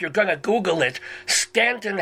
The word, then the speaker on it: Staunton: But in Virginia, Staunton is pronounced Stan.